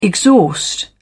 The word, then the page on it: exhaust: British English